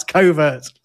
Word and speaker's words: covert: And it's thought that they look very similar for the same reason that we pronounce covert like overt.